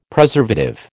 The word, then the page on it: Preservative: American English